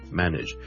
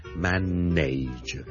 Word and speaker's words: manage: 今天想跟大家講 manage 這個字的發音 manage M-A-N-A-G-E manage。由於這個字後面 N-A-G-E nudge 是串做 N-A，好多人就會將它讀成 manage，但是事實上呢，我這個字是讀 manage nudge nudge nudge，你當是好像 N-I-D-G-E 那樣讀 OK manage，